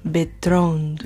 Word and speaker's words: Bertrand: It's Gerard Bertrand? I don't know. – Give it some love. Bertrand. – There you go. Perfect.